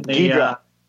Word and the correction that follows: Ghidorah: I never say it right. Ghidorah.